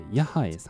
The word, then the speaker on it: YAHAE: 清澄三丁目、清澄長屋の一角にある靴下屋さん、ヤハエさんですね、 YAHAE と書いてヤハエさんです。